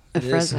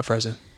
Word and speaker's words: Afrezza: So I uh, just tapped into MannKind and I'm asking about Afrezza. Is that how you say it? – It is. – Afrezza. Mm-hmm. Afrezza. You got it.